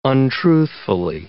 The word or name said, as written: untruthfully